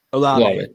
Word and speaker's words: Olave: It's Chris Olave, or is it Chris Olave? – Olave. Yeah. – Olave.